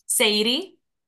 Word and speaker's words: Sadie: And tell me how to say your last name. – Sadie. – Sadie. Did I do it right? – Yeah, you did it perfectly. – Chicago accent.